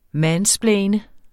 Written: mansplaine

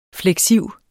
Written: fleksiv

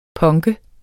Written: PUNKE

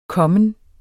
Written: kommen

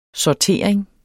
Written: sortering

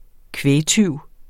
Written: kvægtyv

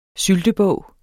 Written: syltebog

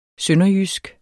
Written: sønderjysk

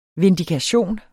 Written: VINDIKATIONERNE

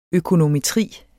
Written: økonometri

